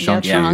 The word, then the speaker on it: Shang-Chi: It's really how you pronounce it. Shang-Chi is how you pronounce it. We've been saying Shang-Chi.